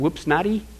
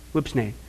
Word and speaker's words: Whipsnade: Or I don't know, is it Whipsnatty? Whipsnade. How would you say it? Snade? Whipsnade. Okay.